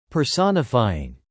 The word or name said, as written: personifying